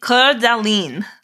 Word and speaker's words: Coeur d'Alene: They took it out on Lake Coeur d'Alene.